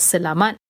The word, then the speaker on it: selamat: And you spell that selamat, S-E-L-A-M-A-T, pagi, P-A-G-I, pagi. Good night, selamat malam, same. Selamat, same spelling. Malam is M-A-L-A-M, which is night.